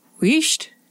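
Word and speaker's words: Uist: So it's Weesht, according to the man on Wikipedia, but it's also a pre-Gaelic word. So nobody knows what it means, and I bet we've all been saying it wrong.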